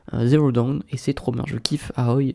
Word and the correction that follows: Aloy: Aoi, ah, oh, Aol, je sais pas comment on prononce. Aloy, Aloy.